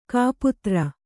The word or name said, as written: ಕೈಪಾತ್ರೆ